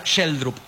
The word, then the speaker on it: Schjelderup: Tal vez el que yo pensaba que se pronunciaba Schjelderup, se pronuncia Sheldrup. Es más cortito, no es tan difícil.